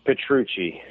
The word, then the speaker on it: Petrucci: It's Brian with an I, and then Petrucci, P-E-T-R-U-C-C-I. Petrucci works fine. Petrucci is going to be accepted in most places.